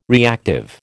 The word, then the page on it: reactive: American English